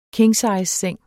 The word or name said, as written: kingsizeseng